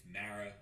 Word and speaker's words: Mara: Chris Mara. Resident Giants fan. Drew never pronounces my name right. It's Mara, like marinara sauce.